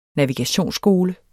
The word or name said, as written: NAVIGATIONSSKOLE